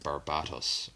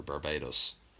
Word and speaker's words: Barbatos: But the Dark Knight's Metal were they all serve like this dark god named Barbatos or Barbados, depending on how you want to pronounce it.